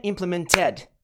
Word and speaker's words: implemented: Então, não é implemented. Esse Ted não é a sílaba tônica. Então, esse padrão de pronúncia se aplica. Então, vamos lá. Implemented. Implemented. M é a nossa sílaba tônica. Implemented. Implemented. Implemented. Implemented. M. Implemented. Eu sempre bato palma na sílaba forte, pra ficar mais claro, tá? Então é implemented, não é implemented, ok?